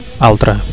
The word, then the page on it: altre: Catalan